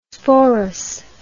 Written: sporous

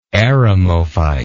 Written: eremophyte

